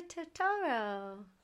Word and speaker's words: Totoro: My Neighbor Totoro.